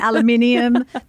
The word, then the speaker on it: aluminium: In aluminium. There's actually an extra I in it. Did you know that? It's spelt differently.